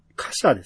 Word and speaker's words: 火車: カシャですね。 – うん。あ、カシャだ。 – はい。 – うん。これはピチさんに分かりやすいように。 – ひぐるまと書いてカシャね。